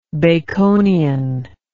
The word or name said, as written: baconism